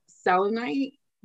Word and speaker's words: selenite: So the selenite, yeah, that's how you say it, right? Yeah.